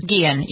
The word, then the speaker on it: Guillen: Guillen, yes.